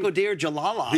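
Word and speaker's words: Bakhodir Jalolov: Jalalov, I can never pronounce his last name. – Bakodir Jalalov.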